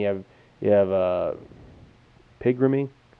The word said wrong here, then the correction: Pigrome: How's it pronounced? – Pigrome.